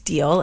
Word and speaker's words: Diehl: Ed Deal, or as his campaign t-shirts like to say, Ed No Tax Deal. And I guess we should point out that that's spelled D-I-E-H-L, but it is pronounced like Deal.